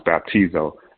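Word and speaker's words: baptizo (βαπτίζω): Baptism in the Greek means, it's stated or pronounced baptizo, being buried in water, fully submerged, buried in a watery grave.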